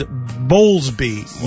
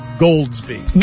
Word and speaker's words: Bowlesby: It is Bowlesby, not Goldsby. – Gotcha! – When he stops talking, I'll call him Bowlesby. When his teams make the Final Four, I'll call him Bowlesby.